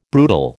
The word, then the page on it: brutal: American English